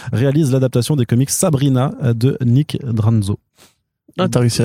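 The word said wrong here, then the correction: Drnaso: Non, c'est Dernazo. Dernazo. Dernazo.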